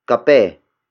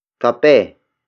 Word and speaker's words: kape: The next word is kape. Kape. Kape. Kape is spelled as K-A-P-E.